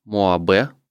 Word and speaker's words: MOAB: Можешь рассказать, как вообще развивалось, вот если кратенько взять, как развивалось Моабе, как правильно, кстати, произносится? Мо. – Моаб, Моаб да?